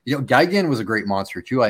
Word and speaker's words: Gigan: I always mispronounce this one. I think, is it Gigan? – Gigan, yeah.